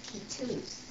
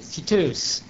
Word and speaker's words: Ketous: I found it K-E-T-O-U-S. Is that correct? – That's correct. – Now, how is that pronounced? – That is pronounced Kitoos. – Kitoos. – The accent on the... – On the E. Kitoos.